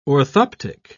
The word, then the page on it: orthoptic: British English